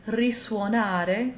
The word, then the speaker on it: risuonare: If you come across a word that starts with an RI as a prefix, the root word that follows will be pronounced the same way it would without the prefix. So in cases like risuonare, to sound again, risorgere, to rise again, and risoffiare, to blow again, the S will remain an unvoiced S.